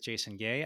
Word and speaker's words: Jason Gay: So got a funny last name, and that funny last name doesn't sound so good when it rhymes with the show name you're doing. So when you're doing the Jazz Cafe with Jason Gay, I ended up changing it for at that point in time.